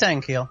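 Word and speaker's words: N'Keal: Is it Enkeel or how do you pronounce his name? Enkeel Harry. – I think it's Enkeel. – Okay. – And it is, yeah. – Like Rick Enkeel.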